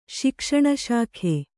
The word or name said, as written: ಶಿಕ್ಷಣಶಾಖೆ